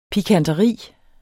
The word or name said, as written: pikanteri